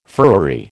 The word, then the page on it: furriery: American English